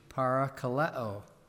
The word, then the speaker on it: parakaleo: It's, if you're interested, the Greek word would be pronounced in English, parakaleo, called alongside is the basic idea of that word, to be called alongside of someone.